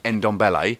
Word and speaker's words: Ndombele: Ndombele.